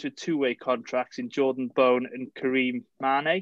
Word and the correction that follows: Mane: Is that how you say it? – Main. – Okay, sorry. There's a footballer called Mane. – We'll call him Mane. – At least I call him Main.